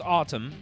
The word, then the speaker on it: Atum: Yeah, Autumn is it's pronounced Autumn, and it's called A-T-U-M is the spelling.